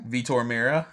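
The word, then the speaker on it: Vitor Meira: Vitor Mira.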